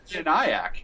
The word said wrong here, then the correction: ENIAC: Yeah, the original computer. – Yeah, geez. – Oh, ENIAC. – Oh, did I pronounce it wrong? – I knew what you were talking about.